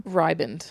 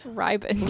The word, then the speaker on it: riband: That's a word that is way too close to ribbon and yeah. then not yeah. – pronounced the same. – Riband. – Riband. – Blue riband.